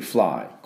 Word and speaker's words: fly: Fly は WC なんで FL でフルッフルッフルッフルッフルッで、 I はそのあの Y は I と発音しますから Fly Fly っていうふうに発音します。 Fly じゃありません。 Fly フルッフルッフルッ、ダブルシーンです。